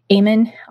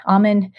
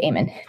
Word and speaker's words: Amen: She studied under I, I always say his name wrong, but it's Dr. Daniel Op- Amen. Amen. I've like heard it both ways, but um, we'll say Amen.